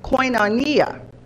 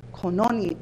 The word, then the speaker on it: koinonia: Now, the word fellowship is derived from the Greek word koinonia. I always have a hard time saying this. So I want to make sure I say it again. Koinonia.